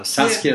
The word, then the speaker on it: Saskia: Saskia, yeah.